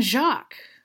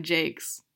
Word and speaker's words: Jacques: But yeah, Chicken Scratch was largely inspired by Redwall, by Brian Jakes, whose last name I finally learned how to pronounce like a couple years ago. reading him in like fourth grade, I was like, oh yeah, Brian Jacques. No, it's Brian Jakes.